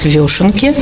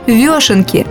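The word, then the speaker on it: вёшенки: Правильно говорить «вешенки», потому что ударение всегда падает на букву «ё». Исправленный вариант говорится только потому, что это удобно людям орфоэпическим. – Итак, правильно, «вешенки».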